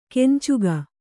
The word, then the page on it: ಕೆಂಚುಗ: kencuga